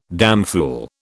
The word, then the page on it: damfool: British English